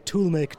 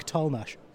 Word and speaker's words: Tollemache: which was spelled TOLLEMACHE. Hyphen the same spelling, but despite the fact that the two names were spelt the same, they were pronounced differently. – No. – So the fellow's name was Toolmake Tolmash.